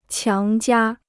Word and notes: 强加: qiáng jiā

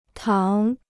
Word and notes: 糖: táng

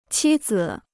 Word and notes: 妻子: qī zi

